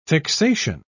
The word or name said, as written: fixation